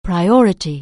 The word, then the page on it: priority: anglais